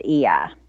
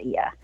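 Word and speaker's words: Oia: And note, the audiobook says Oya, but when we were there on the ground, they said Ia. So just a note. If Effie was listening, she could probably give us the definitive, but I'm pretty sure the Greeks pronounce it Ia.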